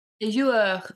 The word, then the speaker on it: Les Joueurs: You do pronounce the R. – Les Joueurs.